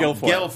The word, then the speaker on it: Guilfoyle: So, for viewers and listeners, Kimberly got, please pronounce her Guilfoyle. – Name. – Guilfoyle.